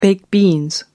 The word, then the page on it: baked beans: American English